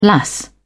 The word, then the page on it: lass: British English